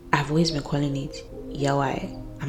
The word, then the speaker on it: Yaoi: It is pronounced Yaoi.